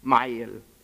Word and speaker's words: Ismail: In the Quran, the pronunciation is Ismail.